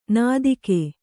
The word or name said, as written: ನೀತಿಕೆ